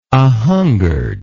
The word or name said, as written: ahungered